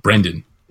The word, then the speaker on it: Brendan: It's such a good show, and it's why the hell did they have Brandon Fraser on this? – Brendan. – Yes. Yes, him. – With an E.